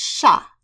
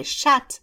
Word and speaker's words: chat: So the French word for cat is chat, and that's the masculine generic form for a cat. Mm-hmm. But if you pronounce the final T in that word and say chat, that's the feminine form, which means pussy.